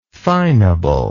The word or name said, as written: finable